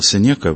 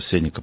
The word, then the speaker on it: Сенека: Я всю жизнь говорил Сенека, потом отучался Сенека правильно говорить.